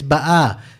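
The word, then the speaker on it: Ba'a (בָּאָה): And Ba'a here, the emphasis is on the second syllable, right? It's Ba'a, as opposed to the later pasuk where it says Rachel Ba'a, which means she came. That's the past tense. But here it's the present tense, Ba'a.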